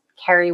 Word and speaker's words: Carrie: So it's carrie.a.fitzgerald and that's Carrie with R-R-I-E.